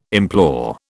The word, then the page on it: implore: British English